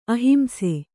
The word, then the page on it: ಅಹಿಂಸೆ: ahimse